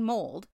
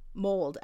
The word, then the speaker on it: Mold: Wrexham and Mold lies a grade one listed Jacobean house called Plastag. – Mold? – Mold. M-O-L-D. Yeah. – Okay. – I'm assuming it's mold still. Like, okay. That's how it's spelled.